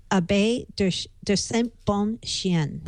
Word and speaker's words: Bon-Chien: And it's, Leo can correct my pronunciation because it's a French name. Abbé de Saint-Bon-Chien. – Bon-Chien. Means good dog.